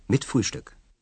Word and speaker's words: mit Frühstück: 120 mark, mit Frühstück.